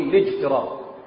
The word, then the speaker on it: الاجتراء: الاجتهاد وحاجة اسمها الاجتراء، يعني بيقولوا الاجتراء، بس هنا الهمزة همزة وصل، احنا بنقول الاجتراء.